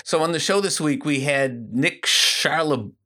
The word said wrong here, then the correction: Charlebois: I always say it wrong. I'm good at saying names wrong. – Charlebois.